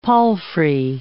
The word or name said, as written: palfrey